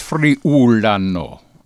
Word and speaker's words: Friulano: Now, how do you say that again? – Because Friulano. – Yes, it's Friulano. – Correct.